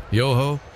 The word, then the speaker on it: Yoho: Y-O-H-O, Yoho. Pronounced exactly as you would expect.